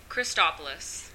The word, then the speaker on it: Christopoulos: That's P-E-N-E-L-O-P-E, right? – Correct. – And what's your last name? – Christopoulos. – And how do you spell that? – C-H-R-I-S-T-O-P-O-U-L-O-S.